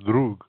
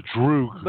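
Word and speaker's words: drug (друг): Well, you can't say drug. You know, Megadeth fans are called droogies. And he got it from Russian, from the Russian word for friend, which is drug. Mm-hmm. But he goes droog.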